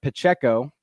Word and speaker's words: Pacheco: Running back Isaiah Pacheco, I have checked the pronunciation on that, for Rutgers.